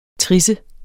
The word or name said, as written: trisse